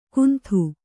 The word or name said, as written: ಗುಂತು